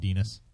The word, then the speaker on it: Dinas: There's a character in there named Dinas. Rhymes with penis.